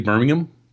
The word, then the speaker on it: Birmingham: It's actually Birmingham, but Birmingham. Did I say Birmingham? – It happens. – I can't even say it right now, but you know what I meant to say. – You have to say it in the British pronunciation.